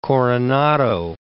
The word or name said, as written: Coronado